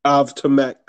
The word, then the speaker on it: Avtomat: Automat? How you say it? – Automov Kalashnikov. – Avtomat Kalashnikov. Yeah.